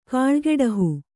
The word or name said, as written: ಕಾಳ್ಗೆಡಹು